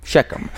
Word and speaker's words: Shechem: We realize that the incident in Shechem, did I pronounce that correctly? – Shechem. – That the incident in Shechem, in Portuguese we say Siquém. So it's very different, you know, Siquém to Shechem.